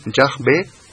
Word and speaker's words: Yahvé: Por tanto, esto da también preeminencia a la pronunciación Yahvé, de nuevo como la correcta.